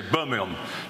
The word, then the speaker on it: Birmingham: I must remember to emphasize every syllable, because in Britain we say Birmingham just like that.